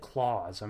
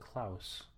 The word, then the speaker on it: Klaus: They don't say it Claus. I'm pretty sure they pronounce it Klaus.